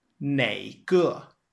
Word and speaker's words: 哪个: Note that 哪个, or you can pronounce it as 哪个, can also be expressed as 哪一个, or you could say 哪一个, with the same meaning and usage.